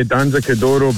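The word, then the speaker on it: Danza Kuduro: Can you either play Danza Kodoro by Don Omar or Bob Sinclair Love Generation? – Because you went to the effort to pronounce that correctly, I think it has to be Danza Kodoro.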